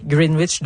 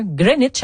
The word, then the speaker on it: Greenwich: Et là, on m'a signalé que on ne doit pas prononcer green comme green, mais plutôt green. Et on ne prononce pas le W, donc ça fait un Greenwich.